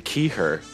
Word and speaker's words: Keigher: It's actually Keigher, silent G. – Silent G? Wow.